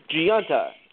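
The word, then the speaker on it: Gionta: How do you pronounce his last name? Brian's his first name. – Gianta. – That's it.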